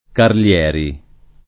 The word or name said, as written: Carlieri